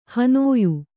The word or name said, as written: ಹನೋಯು